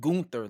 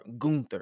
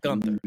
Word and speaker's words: Gunther: And if you follow him on Twitter, he's the reason why I pronounce it Gunther, like G-O-O-N. T-H-E-R, Gunther, and not Gunther, because of Malcolm Bivens.